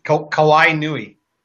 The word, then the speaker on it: Kawainui: Kawainui.